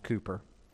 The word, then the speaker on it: Cowper: It's spelled Cowper, pronounced Cooper.